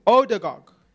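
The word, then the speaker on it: Ødegaard: I hope I say his name right. Oligarch. – Oligarch. – No, I'm saying his name so wrong. I'm butchering his name. I am so sorry. Odegaard.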